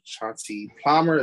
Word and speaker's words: Chauncey Palmer: We have Chauncey Palmer.